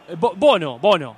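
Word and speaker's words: Bounou: ¿Qué le digo, Bonu o Bono? – Bono. Yacine Bono. – Hoy me mandó el Budo una pronunciación y sí. – Bono. – Si querés es un poco más francés, pero más conocido por nosotros como Bono.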